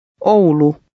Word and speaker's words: Oulu: The OU letter combination is pronounced O. Try to read number 5. – Oulu.